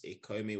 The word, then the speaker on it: Ikomi: So my LinkedIn is Oris Ikomi, which is O-R-I-S, and surname is spelled I-K-O-M-I.